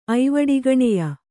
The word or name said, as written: ಐವಡಿಗಣೆಯ